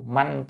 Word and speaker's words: manh: manh tu hay vong vọng tu cũng được, nhưng mà ở đây ấy, nên đọc là manh, mạnh thì đúng hơn nha.